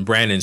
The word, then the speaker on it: Brannon: Bar Basic is at 5 and some numbers Brennan Street. – 510 Brennan Street. – There you go. – 510 Brannon Street.